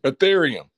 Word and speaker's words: Ethereum: Excuse me if I pronounced this wrong. Ethereum. – Ethereum.